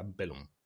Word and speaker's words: Blenheim: se escribe Blenheim como la ciudad inglesa, em, con el acento neozelandés se pronuncia Belum.